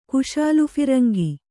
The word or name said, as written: ಕುಶಾಲುಫಿರಂಗಿ